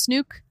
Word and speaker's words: Snook: Apparently her name is Snook. – Oh, I did see a clip of her explaining why or how to pronounce her name. It's Snook. – We've been saying it wrong for years.